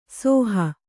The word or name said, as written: ಹೋ